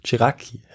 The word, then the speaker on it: Ceracchi: Eines der Mitglieder dieser Gruppierung, der das Ganze mit Schrecken wahrnimmt, dass Napoleon jetzt erster Konsul ist, ist der italienische Künstler Giuseppe Ceracchi, ein Jakobiner. Ja. Habe ich hier richtig ausgesprochen? – Das klingt gut, ja, oder? – Ceracchi, ja, genau.